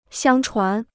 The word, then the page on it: 相传: xiāng chuán